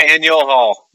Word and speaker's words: Faneuil Hall: Faneuil Hall.